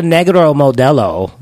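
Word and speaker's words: Negra Modelo: And it's called a Negro Modelo. That's like the correct way to say it, not nigger Modelo, which is what he said.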